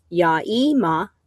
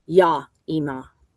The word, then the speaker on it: Yaima: So it's Ya-ima or Ya-ima, spelled Y-A-I-M-A.